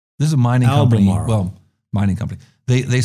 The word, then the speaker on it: Albemarle: Well, I want to talk about an interesting company in the lithium business called Albemarle. – How do you pronounce that? Albemarle? – Albemarle.